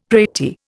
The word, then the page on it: pratie: Indian